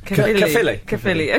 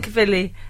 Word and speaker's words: Caerphilly: Oh, Bill in Caerphilly. Says, oh, that sounds good, doesn't it? – Yeah, it's like Lily Allen. – Yeah, Bill and Caerphilly says, the annoying phrase is, he turned round and said.